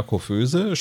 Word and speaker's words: Coiffeuse: Ja, Koföse spricht sie das anstandslos Französisch aus, ohne Akzent und absolut lupenrein, oder?